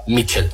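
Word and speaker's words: Míchel: His full name is Miguel Ángel Sanchez, but we all say to him Michel.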